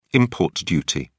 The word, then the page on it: import duty: British English